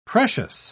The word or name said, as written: precious